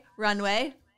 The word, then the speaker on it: RNWY: Anyway, this episode is, again, sponsored by Runway, R-N-W-Y, no vowels.